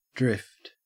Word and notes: drift: Australian English

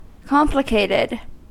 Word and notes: complicated: American English

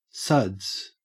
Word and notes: suds: Australian English

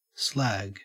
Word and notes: slag: Australian English

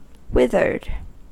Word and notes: withered: American English

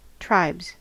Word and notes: tribes: American English

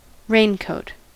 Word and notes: raincoat: American English

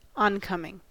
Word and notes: oncoming: American English

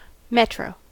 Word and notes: metro: American English

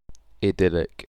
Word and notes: idyllic: British English

